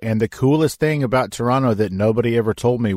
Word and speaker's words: Toronto: Well, one of the things that Toronto has to offer, well, first of all, you can't say Toronto. You have to say it like there's no first O. So it's just T-R-O-N-T-O, Toronto. That's how you have to say it when you get there.